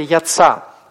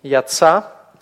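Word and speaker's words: яца (יצא): Вместо этого алев просто сливается на конце слова с предыдущей гласной. Как, например, в слове «яца». Вот вы уже учили этот глагол «яца». Смотрите, как произносится. Не требуется никакого дополнительного значка. Здесь «яца».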